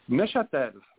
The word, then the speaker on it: Neuchâtel: Neuchâtel, like Newcastle.